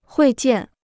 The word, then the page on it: 会见: huì jiàn